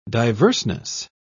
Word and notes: diverseness: British English

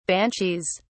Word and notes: banshees: British English

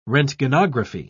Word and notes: roentgenography: British English

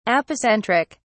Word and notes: apocentric: British English

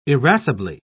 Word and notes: irascibly: British English

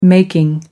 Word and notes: making: American English

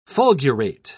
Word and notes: fulgurate: British English

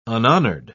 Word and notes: unhonoured: British English

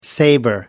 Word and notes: saber: British English

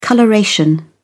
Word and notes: coloration: British English

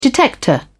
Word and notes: detector: British English

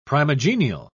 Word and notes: primigenial: British English

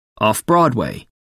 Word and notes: off broadway: American English

